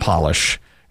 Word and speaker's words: polish: It's Polish and polish. It's spelled P-O-L-I-S-H, Polish, and polish.